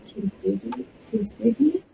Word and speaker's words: kintsugi: There's that Japanese tradition called kintsugi, that's how you pronounce it.